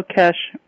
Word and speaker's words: Keshe: And how do you spell Kesh? – K-E-S-H-E. – Okay. Kind of like it sounds, except with a silent E.